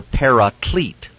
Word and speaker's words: paraclete: It is parakletos, or if you prefer, paraclete in the anglicized version.